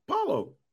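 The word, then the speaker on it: Paolo: How is this how you say his first name? – Paulo. – Paulo. – Like Paulo. – Like Apollo, but it's just Paulo.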